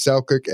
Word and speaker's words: Selçuk: I probably mispronounced that name, but it's Selçuk, S-E-L-C-U-K, Turkey.